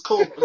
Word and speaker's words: Culver's: He or she hit us up, man, last week talking about how they love the show and how I mispronounced Culliver's and it's supposed to be. He was like, the show good, man, but just learn how to say Culver's. It's not Culver's, it's Culver's.